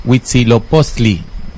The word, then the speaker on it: Huitzilopochtli: Huitzilopochtli, dice Andrómeda, buena pronunciación, gracias.